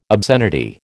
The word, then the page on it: obscenity: American English